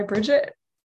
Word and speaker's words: Brigitte: What's the correct pronunciation? Bridget. – No, no, but it's spelled Brigitte.